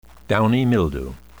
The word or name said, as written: downy mildew